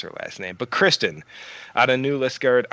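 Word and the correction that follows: New Liskeard: Ontario, Maybe even I screwed up the town in Ontario. – No, New Liskert, that's what it's called. – New Liskert, okay.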